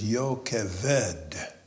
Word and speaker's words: Jochebed: The Hebrew pronunciation is Jochebed.